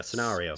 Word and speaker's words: scenario: Or rather, a scenario. – A scenario? – Yes. Well, pronounce the A in a harder tone. A scenario.